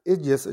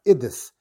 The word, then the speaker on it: Idis: In fact, the Teres Yisrael says that it shouldn't be pronounced Igis, it should be pronounced Idis.